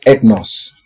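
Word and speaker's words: ethnos (ἔθνος): You know, the Greek word translated nations and Gentiles in most parts of the New Testament is the word ethnos. This word ethnos is spelled E-T-H-N-O-S, and it refers to a group of people, irrespective of geopolitical boundaries, who have similar lifestyles and therefore similar circumstances.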